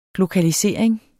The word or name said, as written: glokalisering